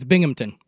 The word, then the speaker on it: Binghamton: Someone on the text line says that's not how you say Binghamton. – No, it's Binghamton.